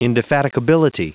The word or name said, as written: indefatigability